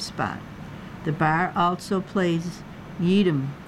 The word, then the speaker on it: YEEDM: She often books local DJs who specialize in the crossover between country and electronic dance music, popularly known as Yeedem, Y-E-E-D-M, pronounced to rhyme with Yeedem.